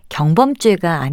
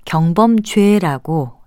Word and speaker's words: 경범죄: 그런데 이것은 가벼운 범죄라는 뜻으로 가벼울 경자와 범죄라는 명사가 합해진 것이기 때문에 경범죄가 아니고요. 경범죄라고 예사소리로 발음하는 것이 맞습니다.